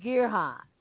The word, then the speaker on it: gerahs: A shekel is 20 gerahs. 20 gerahs, okay? That was the name of the money in Israel. Gerahs. Gerahs. Gerahs. That's how you pronounce it in the Hebrew.